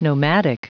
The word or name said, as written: nomadic